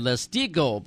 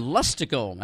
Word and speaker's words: Leistico: Well, I tell you what, I said Leistico, but I like your pronunciation better than mine. L-E-I-S-T.